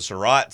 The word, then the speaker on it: Surratt: Local golf story, as Caleb, is it Surratt? Is that how you say his name? – Yes.